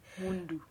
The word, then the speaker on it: Mundu: And this guy called Mundu, I think I'm saying it correctly. – Mundu. – M-U-N-D-U.